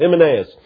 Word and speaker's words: Hymenaeus: And Brother Steve, I looked that up this morning, trying to figure out how to say his name. There was a little question last night. I wasn't 100% sure, but that's how it's been. Alexander Scorby read it on the radio or on the computer this morning. So I'm going with Hymenaeus.